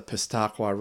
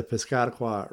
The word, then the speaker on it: Piscataqua: At which point you're on 95, which takes you onto the main turnpike at the Piscataqua River Bridge. Yeah. I can never pronounce that fucking word. The Piscataqua River Bridge. So I know there's Piscataquis County. So I guess it's the Piscataqua River Bridge.